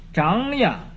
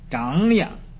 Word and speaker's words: 长养: 所以说，信为道源，功德母，长养一切诸善法。这是个本来是个长字，但是啊，要画一个这个上升的这个圈啊，所以读成一个长长养啊，不是长养啊。